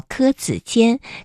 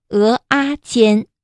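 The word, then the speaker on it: 蚵仔煎: 还有的朋友呢，在泉州喜欢吃萝卜饭啊，萝卜饭是比较朴素的，配上几串简简单单的铁板烧啊，有浓烈家乡味的牛肉羹，还有红赖鸡爪、柯子煎。我们说它的正常读音应该是叫柯子煎，可是闽南语呢就读作鹅阿煎。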